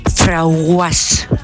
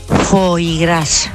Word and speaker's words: foie gras: Pues yo tenía una clienta que cuando me iba a comprar cierta cosa decía, dame un frauguas. A ver si sabéis lo que es eso, porque yo el primer día no lo sabía. – ¿Eh? – Foie gras.